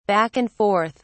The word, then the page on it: back-and-forth: British English